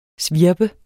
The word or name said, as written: svirp